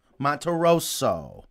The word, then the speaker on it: Monterroso: Dalia Montessoro Rosso. I butchered it, didn't I? – Close. Monterosso. – Monterosso. – A lot of people say Montessoro, and some people say Monterasso. So.